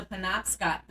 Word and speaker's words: Penobscot: So we had to go to the Penobscot building. – Oh, you said it right. – I did? – Yes.